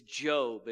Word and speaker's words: Job: For those of you that aren't familiar, it says Job in your Bible. But it's Job is how it's pronounced.